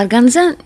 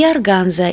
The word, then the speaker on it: органза: Слово «органза» неизвестного происхождения, не устоявшееся в языке, и хотя сама ткань весьма древняя, известно про нее мало. Поэтому произносят название ткани и органза, и органза, на французский лад, хотя название к Франции не имеет никакого отношения.